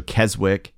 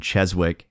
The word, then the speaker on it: Keswick: So Keswick and Skipton are examples of this. In their Anglo-Saxon days, they would have been Cheswick and Shipton.